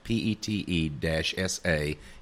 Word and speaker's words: pizza: He had to show him how to pronounce it. It would have like PETE-SA in parentheses after the word.